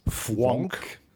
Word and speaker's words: Fvonk: gemeinsam mit der Susanne Höllier eine Sendereihe, die heißt Fwonk. – Fwonk dich frei. – Und Fwonk schreibt man F-V-O-N-K.